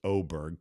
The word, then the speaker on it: Åberg: Could be, you know, it's funny, Ludvig Åberg, which is pronounced Åberg because the Oberg, A has a stupid little zero on top of it.